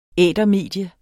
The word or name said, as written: ætermedie